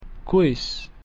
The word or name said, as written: quis